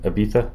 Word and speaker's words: Ibiza: Ibiza? Okay. – It's like a soft Z.